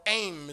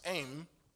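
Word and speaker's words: aim (אם): Now that word mother is a Hebrew word im, or aim is really how you pronounce it, aim, which means mother of Deborah's relationship to the people.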